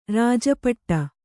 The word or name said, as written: ರಾಜಪಟ್ಟ